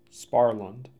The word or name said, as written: Sparland